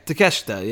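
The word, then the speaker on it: Takeshita: The MJF Takeshka match, I'm not a big fan of Takeshka, whatever, however you say his name, I think it's Takeshka, right? – Yeah, Takeshka, yeah.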